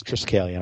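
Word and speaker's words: Triskelion: I have double air servant, and a Triskatillion, which that is a bomb in the rare slot. – Is it Triskalion or Triskatillion? – It is Triskalion.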